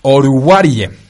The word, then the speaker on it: Oruwariye: Con los Detroit Lions toman al cornerback de Penn State, Amani Uruguay. siempre me falla este apellido. Oruwariye. Amani Oruwariye. Ah, ahí está, perfecto, ya como africano lo pronunciamos.